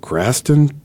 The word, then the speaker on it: Graston: And then one of these other things I see on here is you have experience in Graston technique? Am I saying that right? – You are, yes.